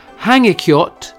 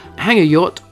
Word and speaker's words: Hangikjöt: H-A-N-G, that's the hang, I-K-J-O-T, but remember the J is more of a Y sound normally in these Scandinavian places. So in Iceland, hangajot, or if you want to be like me, hangikjöt, is the traditional Christmas meal.